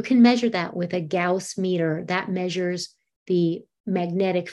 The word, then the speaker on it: Gauss: And Gina would also like to know, I hope I'm pronouncing this wrong, right, G-A-U-S-S-I-N, Gaussian? A Gaussian meter? – Oh, a Gauss meter.